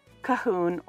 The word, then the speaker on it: Colquhoun: Clan Cahoon is an old clan in Scotland, and it's actually spelled C-O-L-Q-U-H-O-U-N. But I wanted to make sure that I was saying it right, and it is pronounced Cahoon or Cahoon.